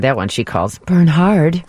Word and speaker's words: Bernhard: It's Bernard. Just think Bernhard. It's a forest. – That's not a normal name. Bernhard. – Just think of it like a German pronunciation.